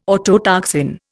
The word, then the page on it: autotoxin: Indian